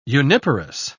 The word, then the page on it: uniparous: British English